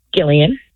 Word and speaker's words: Gillian: Gillian. – Gillian? – Yes. – Not Jillian, but Gillian? – Hard G. There's a story.